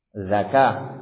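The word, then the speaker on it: zakah (ذكاة): Ini juga hati-hati, menyembelih itu pakai zal, zakat, zakah. Kalau pakai zai maknanya zakat. Hati-hati, satu huruf dalam Al-Qur'an salah membacanya bisa lain maknanya.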